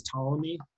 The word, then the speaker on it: Ptolemy: So at the time, his generals, I guess the most famous one that we know of now is Ptolemy, but spelled with a P, P Ptolemy, right?